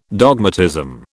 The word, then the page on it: dogmatism: British English